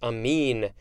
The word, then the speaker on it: amine: Next, we have amine or amine. No, it's amine, A M I N E.